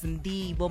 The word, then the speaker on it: Ndivho: My name is Ndivho Makhwanya. It is spelled out as N-D-I-V-H-O.